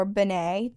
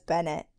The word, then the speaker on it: Bennett: And the Bennett family, or Benet, depending on if you came over with the French pronunciation or if you changed it. My grandmother, her last name is Bennett, and she says Bennett, so that's how I will say it.